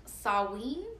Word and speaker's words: Samhain: And okay, I used to think that it was pronounced Samhain because that's how it's spelled. But apparently, just like with a few other Irish words that are not spelled the way they sound at all, Saoirse Ronan, it's pronounced Samhain, like Samhain, or Samhain. Like, it depends on how you, I don't know, whatever, it depends on your accent, I guess.